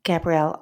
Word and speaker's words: Gabrielle: You can also follow me on Twitter to get updates about new podcasts and more, at Gabrielle Author.